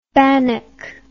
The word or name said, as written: banak